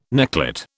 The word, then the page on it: necklet: British English